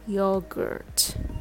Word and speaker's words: yogurt: The rhythm goes tada. Yogurt. When you say gurt, just pretend you're a dog. Grr. Grr. Get this R sound right and add T sound at the end.